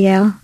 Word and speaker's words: Yael: I mean, I go through ebbs and flows of if I post or not, but just at Yael, Y-A-E-L. – How do you pronounce it? – I say Yael. I know it's Yael. Where I know everyone who's Jewish wants to call me Yael.